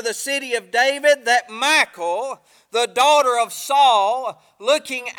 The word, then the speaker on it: Michal: And I want to try to preach this morning for a little while on the subject, why, and her name is pronounced Michal. I always say Michal, but it's Michal.